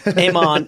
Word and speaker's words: Iman: Iman is I-M-A-N, like I-Man. Mm-hmm. Barlow, yeah. – Iman Barlow, yeah. B-A-R.